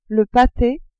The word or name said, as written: pate